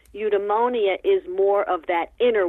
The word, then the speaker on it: eudaimonia: So again, this is a Greek term, and I'm trying to say it correctly, eudaimonia.